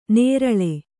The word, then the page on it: ನೇರಳೆ: nēraḷe